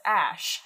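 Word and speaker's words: Æ: It's just X, the letter X, and then the character is pronounced Ash, and then A-12 is my contribution.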